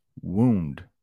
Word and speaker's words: wound: Past tense is wound. That's how you pronounce that. Wound. I hope that is clear. The past tense of wind. This is wound.